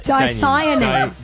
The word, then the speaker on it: dicyanin: I've got it written phonetically here. Dicyanin.